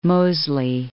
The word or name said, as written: Mosley